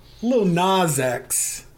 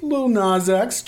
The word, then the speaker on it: Lil Nas X: I pronounced it like Nas, like the pop star. – So all right, just got to back it up. It's just Lil Nas X. Just let it flow.